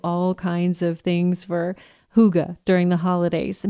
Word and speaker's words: hygge: It's spelled H-Y-G-G-E, but you say it hygge. Hygge, okay?